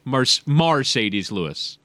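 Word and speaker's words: Marcedes: His name is spelled like a Mercedes-Benz, so why wouldn't you call him Mercedes? But it is indeed Mercedes Lewis.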